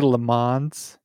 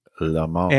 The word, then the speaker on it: Le Mans: Did you just say Le Mans? – It's my Chicago accent, okay? Le Mans. Oh, is that better? – So, so that's French-Canadian. What is it pronounced? – Le Mans.